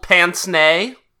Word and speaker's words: pince-nez: a gold pince-nez.